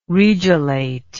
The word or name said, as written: regelate